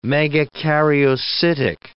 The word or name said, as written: megakaryocytic